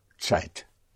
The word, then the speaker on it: Zeit: It's spelled Z or Z, E-I-T. And that is pronounced like a T-S, Zeit.